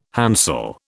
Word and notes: handsel: British English

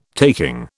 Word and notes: taking: British English

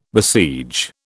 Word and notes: besiege: British English

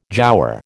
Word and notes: jowar: American English

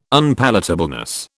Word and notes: unpalatableness: British English

